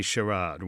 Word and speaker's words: charade: Because they say, I ought to say charade, actually. I'm going on UK telly next week, so I've got to remember the Britannic pronunciation. So I should say charade.